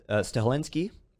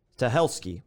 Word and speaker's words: Stahelski: But John Wick, director, Chad Stahelski, I pronounced his name completely wrong, Stahelski. I keep wanting to put an N in there. I do that every time.